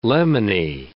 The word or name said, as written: lemony